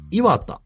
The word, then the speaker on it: 岩田: 1 期目は岩田、作品では渋谷と同じアクセントでしたが、つまり岩田ってことですね。 – あ、はいはいはい。 – 地元では名古屋と同じ発音です。岩田ってことですね。